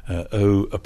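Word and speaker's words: O'Siadhail: O'Shiel, pronounced Shiel, Michal O'Shiel.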